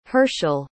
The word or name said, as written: Hershel